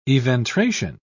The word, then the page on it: eventration: British English